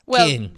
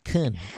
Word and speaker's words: Köln: And in German, it's pronounced Köln. The city is pronounced Köln in German. – Köln. And that's K-O-L-N.